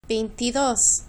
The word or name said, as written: veintidos